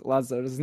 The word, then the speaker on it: Lazar: Some people in Serbia don't know how to pronounce Lazar's name properly. Let alone Americans. – What? How do they pronounce your name? – They put just like you say, Lazar. We don't say Lazar without the R.